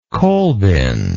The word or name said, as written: coalbin